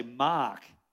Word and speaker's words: Mark: My saying my name, it's like my name is, in Australia, I say Mark. And people, even in America, people go, Mark? Mac? Mick? Mike? So I got to learn to say Mark.